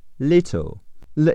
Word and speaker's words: little: Tonight，t a t 是 t o，n 是字母 n，i 是 i g h 组合，n i night，t 是 t，重音在 night，night，tonight，形容词小的 little，l a l 是 l i，t 是两个字母 t，o 是字母 l，结尾的 e 不发音，little little，听力中 t 会变成的，little。